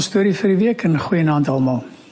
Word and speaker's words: Goeienaand: Goeienaand. I think I said it right. – Ja, yeah, Jim kom al goed reg met die Afrikaans.